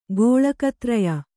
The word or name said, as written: ಗೋಳಕತ್ರಯ